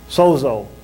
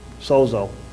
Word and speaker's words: sozo: In each of these cases, the Greek word translated save is sozo, S-O-Z-O, sozo. That's how I pronounce it.